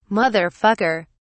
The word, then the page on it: mother fucker: British English